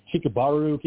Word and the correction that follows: Kirimaru: Kirimaru. – Give me some leeway. Yeah, Kirimaru. Kirimaru. That's it.